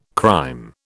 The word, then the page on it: crime: British English